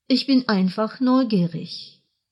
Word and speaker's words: neugierig: Ich bin einfach neugierig. – Вы, несомненно, обратили внимание на то, что в слове «neugierig» последняя буква читается как «ich-laut».